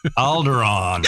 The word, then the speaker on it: Alderaan: It rhymes with Balgaron. – Alderaan. – Yeah, Alderaan.